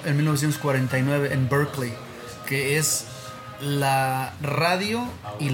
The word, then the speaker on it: Berkeley: No, Berkeley es en Boston. – Ah, okay. – Son 2, se pronuncia igual pero se escribe diferente. – Ah, okay. – Berkeley, California, es cerca de San Francisco.